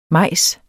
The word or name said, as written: majs